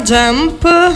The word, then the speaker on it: Jump: Sì, si chiama Jump. Scusate se non lo pronuncio bene, vabbè. – No, è perfetto.